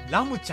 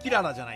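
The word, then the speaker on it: 星心: 星の心と書いて、ラムちゃんらしいです。はい。キララじゃないです。星の心と書いてラムちゃんらしいです。嘘ちゃいますよ。嘘ちゃいますよ。これ。これほんまです。キラ違います。キラコ違いますね。キララ違います。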